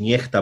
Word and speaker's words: Nexta: Mówimy tutaj o tym kanale na, tele, na Telegramie, kanale Nexta, czy też Niechta, bo i tak można wy, wymawiać ten, mm, te, nazwę tego kanału.